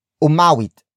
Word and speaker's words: umawit: For example, umawit. Umawit. Umawit. Umawit is spelled as U-M-A-W-I-T.